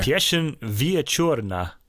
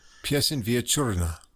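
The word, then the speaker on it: Pieśń wieczorna: Then we've got, let's see, how can Pieszyn, we pronounce this? – I did this. Okay. Pieszyn Wieczorna. – Pieszyn Wieczorna. The evening song.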